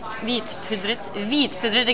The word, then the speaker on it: hvitpudret: Hvitpudret, again that H is silent.